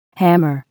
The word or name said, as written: hammer